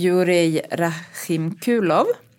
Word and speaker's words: Jurij Rahimkulov: Den här designen, Jurij Rahimkulov. Bra uttal. Jag har frågat honom, jag tror att det var ungefär så man ska mm.